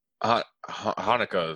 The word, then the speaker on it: Haneke: I've always said Haneke. – Hanukkah is how I when I looked it up, is how people pronounce it.